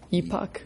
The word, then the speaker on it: epoch: Epoch. E-P-I-C. Epoch. I believe that's the way you pronounce it. It's E-P-O-C-H.